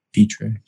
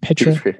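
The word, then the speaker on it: Pitre: Baylor safety, Jalen Pitter, Peter. – Petra. – Can't ever pronounce his name right. Petra.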